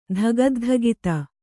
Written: ಧಗದ್ಧಗಿತ

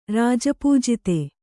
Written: ರಾಜಪೂಜಿತೆ

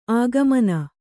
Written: ಆಗಮನ

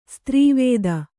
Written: ಸ್ತ್ರೀವೇದ

